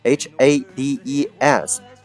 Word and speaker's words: Hades: 这个啊，伊登之前也提到过，就是哈迪斯 H A D E S。